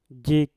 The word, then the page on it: jik: Mpur